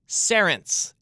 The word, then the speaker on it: Cerence: The name of this company is Cerence. It's spelled C-E-R-E-N-C-E, Cerence.